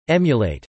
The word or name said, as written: emulate